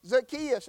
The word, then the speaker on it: Zacchaeus: Now he said some people pronounce it Zacchaeus and some pronounce it Zacchaeus. And he said the proper pronunciation is no doubt Zacchaeus. So I'll use Zacchaeus this morning instead of Zacchaeus.